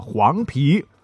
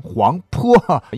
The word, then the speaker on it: 黄陂: 那么在湖北呢，非常非常容易读错的城市啊，排名第一的就是湖北的黄陂。左耳旁右边是一个皮子的皮，很多人会把这个音呢读成黄坡，因为跟这个长坂坡的坡太像了嘛。